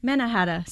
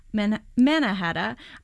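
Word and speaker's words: Mannahatta: Did I say that right? Manhattana. Oh, Manhattan. Manahatta. Sorry, there we go. Manna-hata. I think this is what indigenous people peoples originally called Manhattan.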